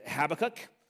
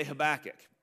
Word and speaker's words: Habakkuk: Now, um, there's a, a, couple different ways that I've heard is Habakkuk. I've heard hot butt kick or Habakkuk or, I mean, whatever variation there. Now, you're going to hear me say Habakkuk and just a scholar I listen to pronounces it that way, so I'm going to lean into that.